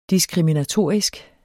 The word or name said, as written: diskriminatorisk